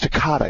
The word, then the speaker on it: Takata: Takeda, I think that's how you pronounce it. Do you remember? Takeda? Or Takata. No, it's Takata.